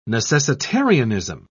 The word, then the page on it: necessitarianism: British English